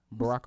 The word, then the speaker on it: Barack: Barack Obama. – Oh, yeah. – Oh, no. – I had Brock's. I'm like, huh? – Yeah, why'd you say it like that? – What'd I say?